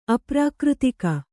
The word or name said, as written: ಅಪ್ರಾಕೃತಿಕ